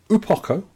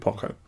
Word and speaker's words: Uo Poko: This is Upoko, or Lakpoko. Lakpoko. I don't know how to pronounce it. It's U-O-Poko. So we call it Upoko.